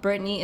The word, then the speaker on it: Brittney: Brittney is spelled N E Y. Don't spell it A N Y because people do do that.